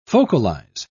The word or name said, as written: focalize